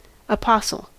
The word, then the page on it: apostle: American English